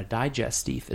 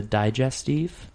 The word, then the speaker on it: digestif: Like Jaeger is a digestif because that's like a little digestive. – Digestif. That's how they say it. – Digestive. – Digestif. It's how you say it.